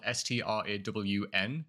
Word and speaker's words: Straughan: Regarding the surname, just pronounce it like it's spelled Strawn and you're all good.